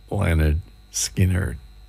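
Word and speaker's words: Lynyrd Skynyrd: This is pronounced Leonard Skinner.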